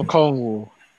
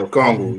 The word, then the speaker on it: Okongwu: Okonegu, or whatever. Okay, okay, Oneka. Oh, Onyeka Okongwu. Yeah, Okongwu.